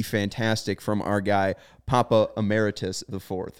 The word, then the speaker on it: Emeritus: Emeritus, right? – Is it Emeritus? – Yeah. – Is it Emeritus or am I just stupid? Emeritus. So yeah.